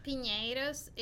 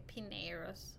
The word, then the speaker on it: Pinheiros: Piñeros is in, Spanish, but in English most people call it Pineros.